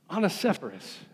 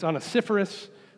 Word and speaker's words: Onesiphorus: Onesiphorus. Actually, it's Onesiphorus. I mispronounced it, but you know, you may not remember him after today anyway.